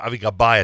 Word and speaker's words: Avi Gabay: Apparently, Avi Gabay is a little too uh, uh Avi Gabay, I think is the right pronunciation. Is, right. A,